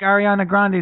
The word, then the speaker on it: Ariana Grande: said, like Ariana Grande is going to be.